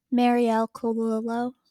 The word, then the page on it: Marielle Colalillo: female